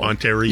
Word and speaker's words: Ontario: Ontario.